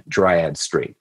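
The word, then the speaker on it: Dryades: And I ran by Brandon, that is the correct pronunciation, Dryad Street.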